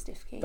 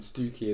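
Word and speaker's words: Stiffkey: I always call it Stifkey. – That's because that's how it's written. Stookey is how it's pronounced. People from the village of Stifkey call it Stookey.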